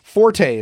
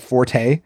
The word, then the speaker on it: forte: If you're discussing someone's forte, as in a strength, the E is silent. Forte is correct only if you're using it as a musical term. So someone's fort. It's my fort. Okay. So you don't say the E. It's not forte.